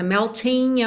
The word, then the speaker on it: melting: Como o G de melting não é pronunciado. Existe a possibilidade de você juntar no N, melting away, mas o Sinatra não faz isso, ele canta melting away.